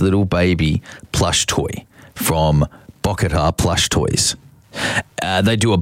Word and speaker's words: Bocchetta: I'll spell it for you. It's BOCCHETTA. Bokatar. BOCCHETTA.